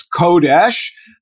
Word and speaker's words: Kodesh: And in fact, this Hebrew root of three letters, Kuf, Dalet, Shin, which we would pronounce as Kodesh, is used often in Jewish liturgical life.